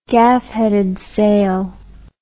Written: gaff-headed sail

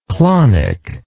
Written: clonism

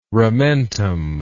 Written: ramentum

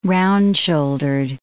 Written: round-shouldered

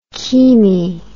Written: chemi

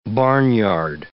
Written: barnyard

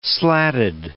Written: slatted

